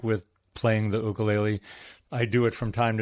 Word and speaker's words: ukulele: Or even more recently, I started playing the ukulele. I use the Hawaiian pronunciation for those of you that think I'm saying it weird.